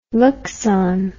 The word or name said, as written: luxon